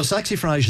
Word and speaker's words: Saxifrage: Okay, Saxifrage, have I pronounced that right? – Saxifrage, yeah.